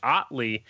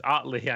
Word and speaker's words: Hartley: He finally sent me a little message, and it's Otley is what he told me how to say his last name. So it's crazy because it looks like Otel or I don't know. It looks like Rotel. I don't know what it is, but it's Otley, I guess.